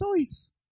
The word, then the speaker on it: Seuss: I found out, by the way, his name is pronounced Soyce. – Soyce? – I know. – Really? – Yeah. – Wow. Dr. Soyce, because he's Swiss.